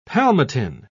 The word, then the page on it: palmitin: British English